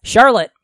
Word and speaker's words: Charlotte: How's it pronounced for real? – Charlotte. Yeah. – Cool. Okay. – Like the web.